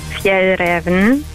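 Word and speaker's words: Fjällräven: Und es wird richtig ausgesprochen, Fjell Raven.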